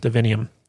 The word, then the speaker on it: Divinium: Divinium. – Thank you. – Rhymes with divine.